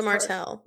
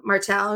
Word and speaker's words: Martel: How do you say her last name? – I can't. Matilda Martel. Martel.